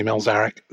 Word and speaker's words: Mielczarek: But for the record, it's Andy Milzarek.